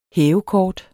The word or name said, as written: hævekort